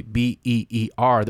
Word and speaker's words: Byrrh: Now, not the B E E R that we're drinking right now. The Mistel. – The um, mysterious B Y R R H is how it's spelled. It's pronounced beer,